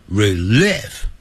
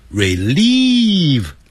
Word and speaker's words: relieve: To case 加上去也对，不加也对，所以 help relieve 可以帮助疏解，relieve 代表疏解，不要念成 relief， relieve 长元音 relieve e 的音。